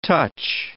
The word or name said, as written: touch